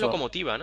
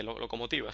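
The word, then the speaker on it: Lokomotiva: El locomotive de Zagreb, eh, la, Locomática de Zagreb, perdón. – El no, creo que es Locomotiva, ¿no? – No sé cómo es es que no estoy seguro cómo se pronuncia, pero. Lo, estoy mirando. NK Locomotiva de Zagreb. Además, es un equipo antiguo, ¿eh? fundado en el 1914, Locomotiva, sí.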